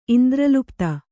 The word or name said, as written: ಇಂದ್ರಲುಪ್ತ